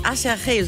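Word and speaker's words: Aserejé: Schon von einer Combo namens Acherje, so wird die ausgesprochen, aber sieht aus wie Acherje. So kann man sich das auf jeden Fall gut merken.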